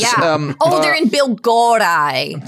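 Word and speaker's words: Biłgoraj: Bilgoraj. – Bilgoraj. – No L sound. – Okay, I'm just going to say it, Bill Garage.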